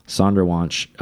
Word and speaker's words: Sonderwunsch: Was that, the Sonderwunsch? – Yeah. – Is that how you even pronounce that? Like it. – Sonderwunsch.